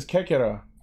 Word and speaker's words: Kekira: Are we calling him Kekiro because he looks like Pepe? – No, his name is Kekira.